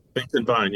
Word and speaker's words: Finkenbine: Finkenbine, you absolutely have it.